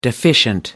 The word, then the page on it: deficient: American English